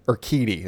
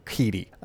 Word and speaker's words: Urquidy: Uh, also, Dusty Baker is going to be throwing Jose or I think that's how you say it. Urquidy? Urquidy. That sounds better. Urquidy.